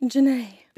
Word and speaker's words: Genay: And then I sound like an asshole when I say, no, it's Janae. – Janae. – Because it is. – It is. – It's not Jenny, like, at all, and it's not a fancy pronunciation of Jenny either.